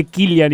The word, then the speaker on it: Cillian: Es que no, pero mucha gente avisame no lo que se dice Killian y Paz tampoco sabía Ese se de risa.